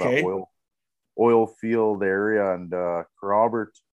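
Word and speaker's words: Kerrobert: You're currently playing for, how do you say, Carrobert? – I think we said Carrobert. – Yeah. It's Carrobert, Saskatchewan.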